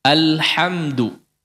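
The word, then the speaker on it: alhamdu (الحمد): Seperti dalam kata alhamdu. Jangan dibaca ol. Ol. Jangan ditebalkan. Tipis. A, a.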